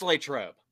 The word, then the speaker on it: Latrobe: But on the pronunciation of Latrobe. – Oh, boy. – It's Latrobe.